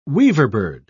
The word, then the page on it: weaverbird: British English